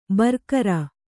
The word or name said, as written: ಬರಿಗೆರೆ